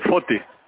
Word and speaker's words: Foti: Yeah, Foti. – How do I spell that? – F-O-T-I. – F-O-T-I.